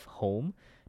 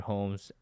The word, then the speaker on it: Hoame: She is a psychotherapist and mental health clinician who is the co-founder of HOME, North America's largest meditation studio, and it is spelled H-O-A-M-E.